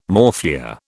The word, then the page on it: morphia: British English